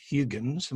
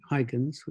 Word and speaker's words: Huygens: English people normally say Huygens, which is Dutch people say Huygens, which you can understand not everyone wants to follow along with.